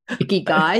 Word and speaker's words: ikigai: Ikigai. – Icky guy, yes.